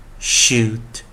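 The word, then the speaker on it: should: 它是一个 consonant diagram 二合辅音，sh sh sh，注意 l 还是不发音哦，shoot shoot shoot shoot shoot shoot。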